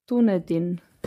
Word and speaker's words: Dunedin: Da hat eine verzweifelte Neuseeländerin in Dunedin, wie spricht man das aus? Sorry. Wie schreibt man es? Dunedin. Dunedin. Danke.